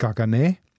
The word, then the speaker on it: Caganer: Geschrieben ist es Kaganer mit C, katalanisch ausgesprochen ist es Gagané.